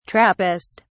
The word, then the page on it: trappist: British English